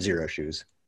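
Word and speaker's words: Xero Shoes: Yeah, Zero. Oh, that's spelled with an X, but said Zero Shoes.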